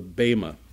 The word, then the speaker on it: Bema: This is where we come to the reward bench, or it's called in the in the scriptures called the Bema, B-E-M-A, long E. B-E-M-A, Bema.